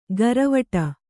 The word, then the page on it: ಗರವಟ: garavaṭa